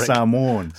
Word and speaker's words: Samoan: you always tell me if I'm not pronouncing Samoa correct. – Samoan. – There we go.